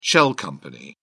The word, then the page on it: shell out: British English